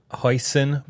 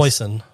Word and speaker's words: hoisin: I got the hoisin barbecue rib. How do you say that sauce? – Hoisin.